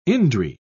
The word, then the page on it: indri: British English